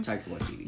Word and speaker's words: Taika Waititi: How do you say his name? – Taika Waititi.